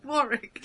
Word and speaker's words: Warwick: Warwick.